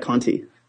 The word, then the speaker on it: Conte: Jack Conti.